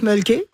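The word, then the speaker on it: Mölkky: Alors, ça s'appelle le Mulky. Ça, ouais. On prononce Mulky. – Ouais. – Et c'est M-O-L-K-K-Y.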